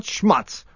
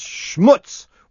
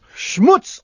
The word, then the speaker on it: schmutz: And I remember this because I got a whole bunch of emails saying that it's not schmutz, rhymes with yutz. It's schmutz, which rhymes with putz. So it's schmutz on the clutch.